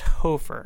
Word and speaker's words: Hoferer: I don't know what the crazy Germans were thinking when they came up with this name, but they're pronouncing it as if there's a silent E-R on it. – Yeah. – It's spelled H-O-F-E-R-E-R. But it's pronounced Hofer.